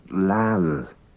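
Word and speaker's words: laccal: And at one time it was pronounced lachal or lachal. But now, in modern Manx, it's pronounced lal. Lal, meaning wanting.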